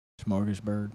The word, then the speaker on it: Smorgasburg: Oh, you only went to Smorgage, Smor, Smor. How do you say the word? – Smorgasburg.